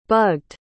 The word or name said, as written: bugged